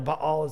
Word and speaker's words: Baal: The Israelites, time and time again, got in trouble with Baal, or Baal is how it's pronounced.